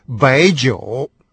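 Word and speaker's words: байджоу (白酒): Если «хун» — это красный, то «белый» — «бай». Это слово, как вы только что слышали, произносится тоже со вторым тоном. И, соответственно, «белое вино» звучит как «байджоу».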